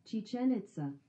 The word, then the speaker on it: Chichen Itza: Chichen Itza.